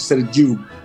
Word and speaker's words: Sergiu: Sergio, pero pues en rumano se pronuncia Sergiu.